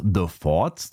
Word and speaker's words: The Forts: The Forts, da muss man aufpassen, auf Deutsch nicht aussprechen, das ist Englisch.